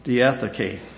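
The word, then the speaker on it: diatheke (διαθήκη): It's from a Greek word you would pronounce diatheke.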